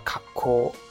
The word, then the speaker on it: 格好: 格好读作呢卡科啊，卡科就是品相，你穿着的衣品怎样？